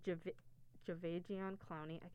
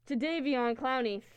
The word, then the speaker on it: Jadeveon Clowney: They did a lot of work on, the pass rush side as they're pairing Myles Garrett and Jadeveon Clowney. That's not even right. – Jadeveon Clowney, thank you.